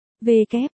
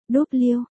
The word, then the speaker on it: W: Cô nhẹ nhàng giải thích, đây là chữ V kép, con có thể đọc là V kép, hay, W, đều được, cách chương.